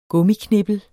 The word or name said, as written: gummiknippel